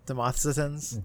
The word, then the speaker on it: Demosthenes: Demosthenes.